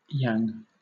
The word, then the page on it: yang: British English